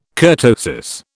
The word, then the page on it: kurtosis: British English